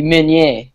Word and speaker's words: Meunier: Well, technically speaking, because it's French, it'd be Meunier. – Okay, versus Jonathan Meunier. He's plus 245. And, I mean, you know, it's interesting because Meunier, he likes to take dudes down and pound them out.